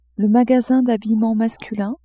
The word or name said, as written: magasin d'habillement masculin